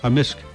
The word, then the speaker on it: amisk: Amisk. – Amisk. So the K can be silent, but in general it's...